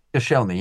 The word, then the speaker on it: Koscielny: Koscielny, was that his name? I can't remember. Koscielny? Koscielny, yeah.